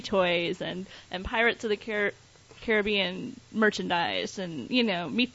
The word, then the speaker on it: Caribbean: I think the correct way to say it would be Caribbean.